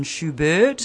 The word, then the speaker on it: Schubert: I see there's Mozart, Schumann, Schubert.